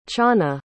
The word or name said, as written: chanah